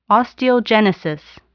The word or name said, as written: osteogenesis